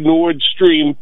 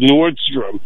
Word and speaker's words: Nord Stream: And at the time, the Nord Stream pipeline, which I think KJP, Kirin, whatever her name was, mispronounces Nordstrom.